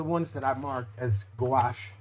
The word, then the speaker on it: gouache: Is it gouache? – Gouache, yeah. – Gouache. You got it.